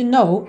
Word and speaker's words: know: Lembrando que esse verbo to know, saber ou conhecer, tem a mesma pronúncia de know, não, ok? O K é mudo e esse N-O-W é fechadinho. No, no.